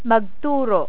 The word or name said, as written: magturo